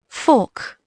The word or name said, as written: fork